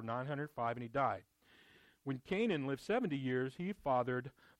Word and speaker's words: Kenan: I know it says K-E-N-A-N, but it's pronounced Canaan. He fathered Canaan.